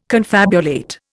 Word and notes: confabulate: Indian

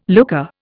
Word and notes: looker: Indian

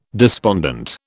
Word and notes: despondent: British English

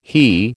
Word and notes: HE: American English

